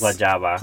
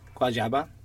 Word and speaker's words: Guayaba: Well, I know how to pronounce the last word. That's yes. – Guayaba. – Please do. Guayaba. – Well, which is, I think it's actually a fruit.